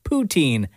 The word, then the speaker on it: poutine: Like instead of poutine, it's either an emphasis on the poo or an emphasis on the teen.